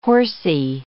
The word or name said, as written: horsy